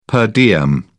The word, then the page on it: per diem: British English